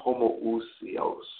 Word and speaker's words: homoousios: The Greek word for same substance, referring to the Father and the Son, is homoousios. Some say homoousios, depending, but I see homoousios, meaning the same substance, meaning that Jesus being one with the Father.